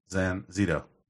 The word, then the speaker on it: Zanzito: Like it sounds. Zanzito. Zanzito. – Oh, Zanzito. – Yeah. – I thought that was a D.